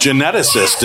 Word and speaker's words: geneticist: Geneticist, isn't it? – Geneticist.